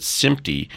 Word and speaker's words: SMPTE: So we pronounce it SMPTE, and it's a reference to the SMPTE standard that's used in motion pictures.